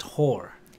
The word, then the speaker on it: Hoare: I used to work with a guy whose surname was, it's spelled H-O-A-R-E, but it's pronounced whore.